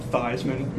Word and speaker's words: Theismann: No, Theismann. – Yeah. – Changed his name to sound like Heisman.